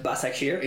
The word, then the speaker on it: Başakşehir: No, but I love the fact that you've learned to pronounce Başakşehir.